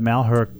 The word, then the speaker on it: Malheur: I knew this at one time, but Malheur? – Yeah.